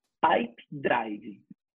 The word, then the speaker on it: Pipedrive: O como é que, repete um pouquinho para gente, como é que fala? – Pipe Drive. P-I-P-E-D-R-I-V-E. Pipe Drive. Tá.